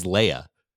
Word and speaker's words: Leah: Sorry. It's spelled Leah. Her name is Leia, because Charlie thinks about Princess Leia every time from Star Wars. – And every time that it's said, he thinks of it as Leia. But later we learn from what's her name, Dora, that it's spelled L-E-A-H.